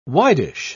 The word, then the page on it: widish: British English